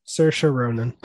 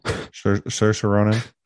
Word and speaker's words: Saoirse: Saoirse Ronan. – Saoirse Ronan.